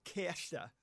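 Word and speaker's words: Kärcher: Es ist ein deutsche Firma und heißt Karcher. – Karcher?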